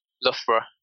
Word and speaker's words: Loughborough: If you could go back to Loughborough, did I say it right? – Loughborough.